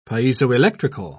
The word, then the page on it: piezoelectrical: British English